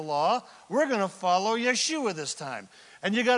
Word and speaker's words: Yeshua: If you're a Hebrew-speaking person, you say Yeshua.